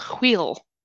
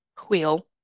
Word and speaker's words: hwyl: Did you know that there's a word in Welsh called chwil, which is spelled H-W-Y-L, chwil.